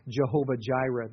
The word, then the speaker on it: Jehovah Jireh: For example, there's Yahweh Jireh, or some pronounce it Jehovah Jireh.